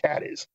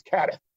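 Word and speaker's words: Cádiz: Cadiz, and as you know, it's not pronounced Cadiz. – Yeah, that's not pronounced Cadiz. It's Cadiz.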